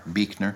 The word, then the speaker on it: Buechner: Well, I, I got the, I take the cue from uh, one of my favorite writers who, named Frederick Buechner um, which is spelled B U E C H N E R, Beekner.